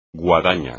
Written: guadaña

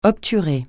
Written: obturer

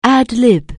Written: ad-lib

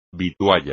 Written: vitualla

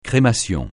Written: crémation

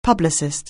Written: publicist

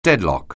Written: deadlock

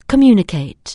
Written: communicate

